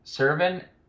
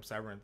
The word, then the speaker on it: Serven: You're skipping Serven. That's a slap in the face to Will Smith. – Probably true. I wasn't even pronouncing Serven's name right. I was calling him Severin, like like Dan yeah, like Severin, Sever- the, the, or uh, like a Severance UFC, package.